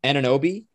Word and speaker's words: Anunoby: Pascal Siakam, Fred VanVleet, who's probably on the other end, and then OG Anunoby. Is that how you pronounce it? – Pretty close. Yeah.